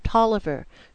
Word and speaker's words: Taliaferro: D-A-R-R-O-W, spells his name Dara, D-A-R-R-A-H. Then we met a Mr. Tolliver, whose name is spelled, lo and behold, T-A-L-I-A-F-E-R-R-O. The Down East Delanos are called Dilno, D-I-L-N-O. The Bolanos name is pronounced Blaino, B-L-A-I-N-O.